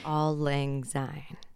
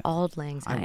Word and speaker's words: Auld Lang Syne: Auld Lang Syne. – There, thank you. I was like, I don't have the confidence to Scottish. – Pronounce it. – I think it's Scottish. – Auld Lang Syne.